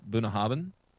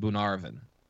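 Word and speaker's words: Bunnahabhain: And so I have pronounced it in the past Bunahabin because that's how it's spelled. It is actually pronounced Bunarvin. – Bunarvin. – Not a B anywhere in there. – Not a bit. And no AR in the middle of it either.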